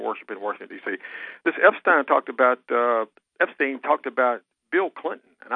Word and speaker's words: Epstein: Epstein.